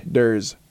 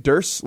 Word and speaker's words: Durs: Durs, yeah. – Am I saying it wrong? – You're saying Durs, like hearse, and it's Durs, like doors.